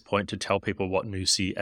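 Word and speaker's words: Nusii: It's spelled N-U-S and double I, Nusi.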